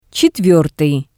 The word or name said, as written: Tsetvertii